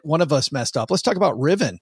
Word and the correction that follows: Rivian: Like, there's one, Rivian.